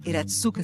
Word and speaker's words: Hiratsuka: Iratsuka se escreve H-I-R-A-T-S-U-K-A.